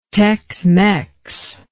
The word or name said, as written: tex mex